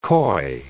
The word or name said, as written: coy